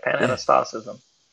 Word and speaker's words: pan-anastasism: and it's called pan-anastasism. Am I saying it right? – Yep, you're saying it right. Pan-anastasism.